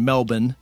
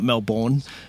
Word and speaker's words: Melbourne: Being from, I'm going to correct your pronunciation, Melbourne, not Melbourne.